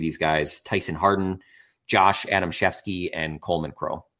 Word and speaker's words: Adamczewski: And then the last one is Josh Adamshefsky. The only reason that I'm somewhat confident in the pronunciation there is that seems like a very German sort of Wisconsin name. It is spelled Adamczewski.